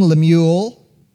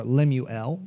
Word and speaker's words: Lemuel: The Bible says this: the words of King Lemuel, or Lemuel, depending on how you want to pronounce it, the prophecy that his mother taught him.